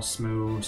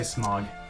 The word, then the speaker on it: Smough: All right, so Ornstein and Smough, not Smog, but Smough, right? – Smough, Smough, Smough, Smough, Smough. Just don't say Smog.